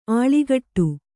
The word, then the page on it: ಅಳ್ಕಾಡು: aḷkāḍu